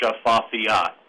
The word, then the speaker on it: Shafafiat: It's Shafafiat. – Okay. Yeah, I've never gotten anywhere close to that. – That's okay. It actually, once you spell it, it's pronounced pretty much the way it looks, Shafafiat.